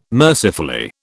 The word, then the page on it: mercifully: British English